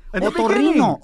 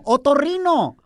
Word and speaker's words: otorrino: No, there's nowhere in the word orinar. Anywhere. Otorrino. Otorrino. – Otorrino.